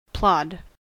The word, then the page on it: plod: American English